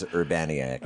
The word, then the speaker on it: Urbaniak: Well, my arbitrary American pronunciation is Urbaniak.